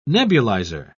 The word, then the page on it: nebulizer: British English